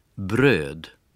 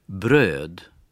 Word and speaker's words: bröd: Одна из них пишется как русская «о» с двумя точечками наверху. Вот как она произносится отдельно. – «Ö». «Ö». – И в таких словах, как «хлеб». – «Bröd». «Bröd». – «Кухня». – «Kök». «Kök». – Это долгий звук.